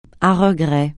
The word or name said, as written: à regret